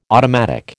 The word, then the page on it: automatic: American English